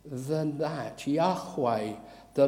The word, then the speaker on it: Yahweh: We pronounce the Hebrew word Yahweh.